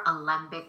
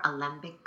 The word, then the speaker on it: Alembik: So my last name is spelled A L as in Larry, E M as in Mary, B as in boy, I K. Jennifer Alembik Coaching.